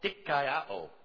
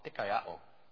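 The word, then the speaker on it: dikaiō (δικαιόω): But the word that we read here in Paul's letter to the Galatians for justify is a Greek word called dikaiō. And it's spelled, if you transliterate it, take it over into English, it's D-I-K-A-I-O-O, and a short O, a long O, dikaiō.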